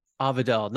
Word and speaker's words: Avadel: A-V-A-D-E-L. – Perfect. Thank you. – Avidel, not Arendelle, where that's right. – Where the ice princess lives.